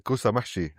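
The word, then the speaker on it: kousa mahshi: Today, I'm joined, who participated in our Bring a Plate video series last year, where he introduced us to kausamashi. Am I saying that right? – Yeah, kusamashi.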